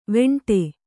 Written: ವಿಂಡೆ

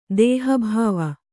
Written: ದೇಹಭಾವ

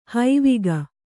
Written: ಹಾವಿಗೆ

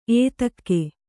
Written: ಎತ್ತಿಗ

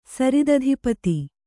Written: ಸರಿದಧಿಪತಿ

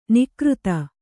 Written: ನಿಕೃತ

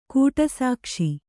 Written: ಕಡುಸೊಗಸು

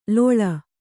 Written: ಲಾಳಿ

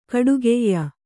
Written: ಖಡಗಾಯಿ

